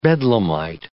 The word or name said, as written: bedlamite